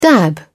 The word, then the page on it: dab: British English